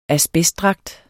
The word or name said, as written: ASBESTDRAGT